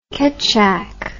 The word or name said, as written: ketjak